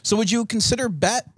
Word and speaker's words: Bette: It's spelled, I mean, it's pronounced differently, obviously, but it's spelled the same way as Betty Davis, right? – Then why isn't it Betty Midler?